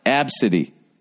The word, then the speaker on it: ABCDE: A mother says that a Southwest Airlines employee poked fun of her daughter's name, which happens to be the first five letters of the alphabet is pronounced absidy.